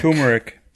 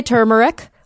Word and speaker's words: turmeric: But yeah, it looks like the accepted pronunciations that I found were turmeric, like T-O-O, even though it's spelled with an R, and turmeric.